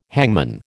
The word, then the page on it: hangman: American English